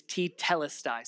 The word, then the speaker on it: tetelestai: And the one word is tetelestai. Say it with me, tetelestai.